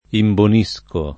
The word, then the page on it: imbonire: italiana